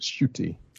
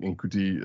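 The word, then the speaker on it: Ncuti: So I quick correction before we get any kind of emails or something, is pronounced Shooty.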